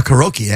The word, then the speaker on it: karaoke: The number one, is it pronounced karaoke? – Yeah. – Technically? Okay. – A karaoke, actually.